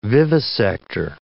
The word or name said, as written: vivisector